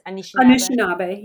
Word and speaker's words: Anishinaabe: Anishinaabe. I'm mispronouncing it. Is it close? – Anishinaabe. Yeah, Anishinaabe. It's fine.